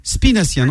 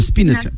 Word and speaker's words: Spinaciens: Comment on appelle les habitants d'Épinay, ouais, c'est les spi, avec un S, je crois. Spinaciens, non? – Avec un S, tout à fait, oui. – Voilà, spinaciens. Tu vois, moi, je prononce mieux que toi.